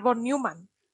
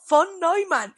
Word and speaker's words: von Neumann: Sí, de hecho a mí en la universidad me echaban la bronca por decir von Neumann. Y me decían, no, von Neumann.